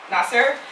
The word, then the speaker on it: Nasser: Nasser.